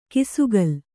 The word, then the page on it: ಕಿಸುಗಲ್: kisugal